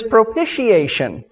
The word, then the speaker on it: propitiation: But it is propitiation, and it is throughout the New Testament in connection with the cross.